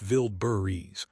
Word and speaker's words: Wildberries: Ukrainian drone strikes on the Russian logistics company Wildberries, pronounced Vildberries, prove that commercial supply chains are now part of the battlefield.